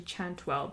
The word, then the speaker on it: chantuelle: I think it was actually chantuel, but it's spelled C-H-A-N-T-U-E-L-L-E.